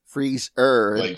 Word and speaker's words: Freez'er: It is spelled Freez, and then apostrophe Er, Freez'er. – Like Freez'er, yeah.